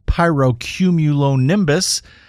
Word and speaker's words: pyrocumulonimbus: something called a pyrocumulonimbus.